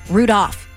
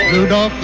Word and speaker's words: Rudolph: Rudolph. – Do you say Salmon too? You don't pronounce the L in Rudolph.